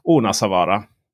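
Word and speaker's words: Ounasvaara: Helt byggd i is och snö från trakten för att bygga den så fick Polestar frakta dit 3000 kubikmeter snö från skidområdet Onasavara. Ja, jag har faktiskt kollat med en finländsk kompis som uttalade rätt och han gav ett ja, godkänt i alla fall.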